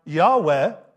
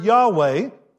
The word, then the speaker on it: Yahweh: The Hebrew would be pronounced something like Yahweh. We're not sure because there weren't any vowels in Hebrew, and the name was never said.